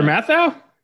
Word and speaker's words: Matthau: Matthau? How do you say it? – Walter Matthau? – Matthau? Yeah. – Matthau. Yeah, Walter Matthau.